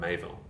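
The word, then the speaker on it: Miéville: Is that how you pronounce that last name, Mayville? – Mayville, yeah. – Interesting. – Yep. So it's M-I-E with an accent over the E.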